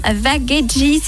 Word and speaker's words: Vagheggi: À ce propos, on met en lumière les soins italiens Vagheggi.